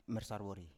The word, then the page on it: mers arwori: Biak